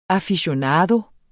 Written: AFICIONADOERNE

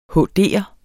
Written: HD'er

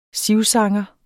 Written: sivsanger

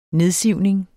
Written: nedsivning

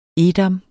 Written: edam

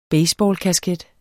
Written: baseballkasket

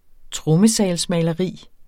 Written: trommesalsmaleri

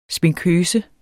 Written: sminkøse

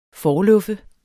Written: forluffe

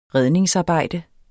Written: redningsarbejde

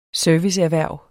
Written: serviceerhverv

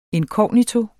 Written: inkognito

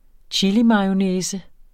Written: chilimayonnaise

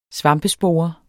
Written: svampespore

